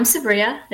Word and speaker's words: Sabrya: So I'm Sabrya, like you said, and beautifully pronounced my name, thank you.